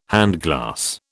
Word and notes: handglass: British English

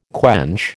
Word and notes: quench: American English

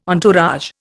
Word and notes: entourage: Indian

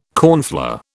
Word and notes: cornflour: British English

